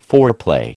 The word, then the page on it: foreplay: American English